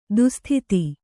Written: ದುಃಸ್ಥಿತಿ

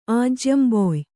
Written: ಆಜ್ಯಂಬೊಯ್

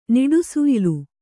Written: ನಿಡುಸುಯಿಲು

